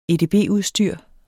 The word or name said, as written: edb-udstyr